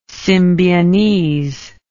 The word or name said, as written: symbionese